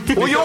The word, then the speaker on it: Ohio: Et ça s'écrit comme ça, mais ça se dit comme ça. – O-Y-O. – Voilà. Non, O-I. Non, O-H-I-O.